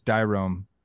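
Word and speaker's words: Dairon: Yeah, I said Darian Blanco. It's Dyrome Blanco.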